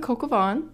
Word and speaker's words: coq au vin: It's spelled C-O-Q space A-U space V-I-N. How would you pronounce that? Not coq au vin, unless you're cultured and you knew that that's how it was pronounced.